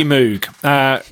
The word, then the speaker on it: Moog: No, I think, we say Moog. I think Moog is much better. – I think we should say Moog. I don't think it's okay that the UK mispronounce a guy's surname and then claim that it's okay because we're not American.